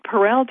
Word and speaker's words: Perel: And what Perel talks about, and her surname is spelled P-E-R-E-L, Esther Perel, and her book, which is outstanding, is called Mating into Captivity.